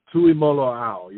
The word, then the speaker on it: Tuimoloau: I'm probably going to butcher his name here, but JT Tuimoloau. Did I say that right? – Tuimoloau, you were right there.